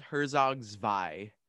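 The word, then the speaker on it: Herzog Zwei: Actually, because it's German, I just wanted to say I think it might be pronounced Herzog's Vi.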